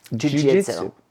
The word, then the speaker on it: Jiu-jitsu: And today, our topic is Jiu-jitsu.